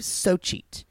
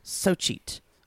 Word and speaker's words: Xochitl: XOCHITL, which is pronounced, I believe, so cheat. So cheat.